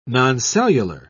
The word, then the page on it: noncellular: British English